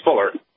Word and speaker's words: Fowler: Fowler, F-O-L-E-R, Fowler. Do I have that right? Am I saying that right? – Close enough. It's Fowler.